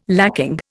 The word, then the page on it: lacking: Indian